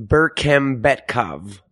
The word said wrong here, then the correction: Bekmambetov: It's Timur Bekmambetov.